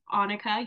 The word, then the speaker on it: Annika: But to start off, hello, my name is Annika. Yes. Pronounced with like an O at the front, not an A.